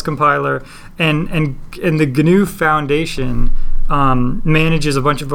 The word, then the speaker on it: GNU: two syllables, GNU. – Oh, it is GNU. – That's what Wikipedia's pronunciation key says.